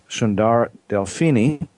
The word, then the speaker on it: Sundara Delphini: Her name is Sundara Delphini.